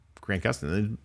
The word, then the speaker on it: Gustin: Yeah, but I like Jason Mendoza as much as the next guy, but like, you know, Grant Gustin, prime opportunity. – You just pronounced that perfectly, by the way. I just want to acknowledge the fact that you just said that last name like.